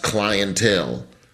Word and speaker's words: clientele: What do you mean it's pronounced clientele? Clientele.